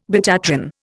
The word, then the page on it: betatron: Indian